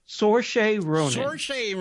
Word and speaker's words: Saoirse: Help me. Suarez Ronan? – Saoirse Ronan. – That's how I pronounce it.